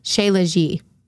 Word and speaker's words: Shilajit: She legit resin. – That's not how you pronounce it. It's Shea Legit.